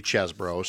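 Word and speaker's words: Chesebro: We talk about Kenneth Cheesebro a lot, this Wisconsin-based attorney. We learned from a Washington Post profile yesterday that it's actually Chesbro, so we'll try to say it right,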